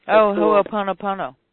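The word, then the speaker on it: Ho'oponopono (Hoʻoponopono): and I never pronounce this correctly, but the Ho'oponopono. – Ho'oponopono. – Yes, thank you.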